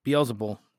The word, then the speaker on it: Beelzebul: And so he goes on to say, now if I drive out demons by Beelzebub or Beelzebul, Beelzebul. Sorry, I've been saying it wrong the whole time.